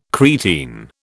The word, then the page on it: creatine: British English